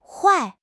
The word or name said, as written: huai4